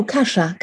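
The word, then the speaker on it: kasza: It's I think you pronounce it kazas. It's K-A-S-Z-A-S. It's like a grain. – Oh, kasha.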